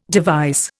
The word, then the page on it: device: Indian